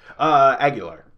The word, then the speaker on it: Aguilar: Aguilar. – Aguilar. – Aguilar. – Aguilar. – Because it's A G U I L A R, so it's Aguilar.